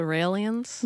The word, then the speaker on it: Raelians: Is it pronounced the Raelians? – Yes, and I interviewed Rael, who has set up a company called CloneAid, where for $200,000, they are offering to clone people.